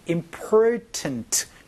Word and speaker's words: important: 자, 여기에서 지금 제가 자꾸 발음을 important 이렇게 안 하고 important 이렇게 하는 이유는 여기에 있는 T 소리를 살짝 는과 비슷한 소리로도 얘기를 할 수 있어요.